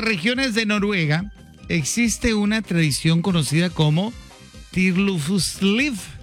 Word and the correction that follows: Friluftsliv: ¿Cómo se pronuncia? Es Friluftsliv.